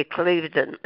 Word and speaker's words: Cliveden: Except my sister-in-law says that we pronounce it wrong. It should be Cliveden. – Cliveden, yeah.